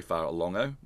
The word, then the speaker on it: Fa'alogo: And we've got Sualauvi Fa'alogo, I don't know him. Sorry, Fa'alongo, there's a silent N in there.